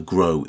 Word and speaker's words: Gróa: Right, it wouldn't be a podcast about Iceland without this band, Gróa. I just absolutely love them. Grow is how you'll pronounce it in English,